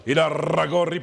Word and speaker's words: Irarragorri: El tema es Alejandro Irarragorri. ¿Lo pronuncié bien? – Irarragorri, porque me corrigió don Jared Borghetti.